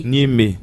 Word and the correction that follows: niébé: Niébé. – Niébé.